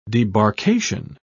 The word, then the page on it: debarkation: British English